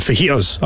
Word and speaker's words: fajitas: You don't pronounce the J in fajitas, apparently.